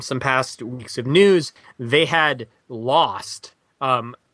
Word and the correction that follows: Nadezhda: Tanakovia. I'm probably totally pronouncing her name wrong again. – Chris Barrett explained it to us. Nadezhda.